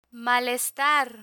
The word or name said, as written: malestar